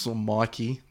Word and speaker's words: Mickey: Mickey is... Mikey. It's spelled Mickey, but I think it was Mikey. – Oh, I think it is Mikey, yeah.